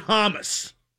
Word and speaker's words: James: I like him because his name is James Rodriguez, but he pronounced it Hamas.